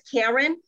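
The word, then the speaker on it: Caryn: I feel a little alienated, but if you would address all Karen questions to me, my name is Karen, and I would appreciate if you spell it right wherever this goes. It's C-A-R-Y-N, okay? Thank you.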